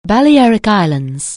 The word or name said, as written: Balearic Islands